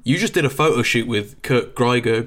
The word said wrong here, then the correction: Geiger: Grieger. I don't know how to pronounce it. I think it's a Grieger. Is um, it uh, Greiger? – Geiger, I believe. Geiger, Geiger even yeah. even worse. Yes, I, I got it wrong both times.